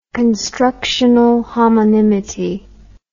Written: constructional homonymity

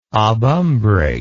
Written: obumbrate

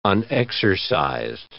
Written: unexercised

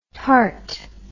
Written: tarte

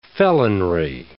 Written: felonry

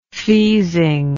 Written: feazing

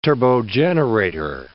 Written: turbogenerator

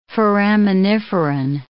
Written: foraminiferan

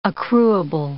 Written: accruable

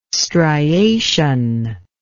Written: striation

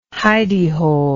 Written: hideyhole

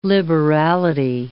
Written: liberality

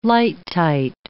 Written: lighttight

